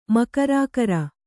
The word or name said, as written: ಮಕರಾಕರ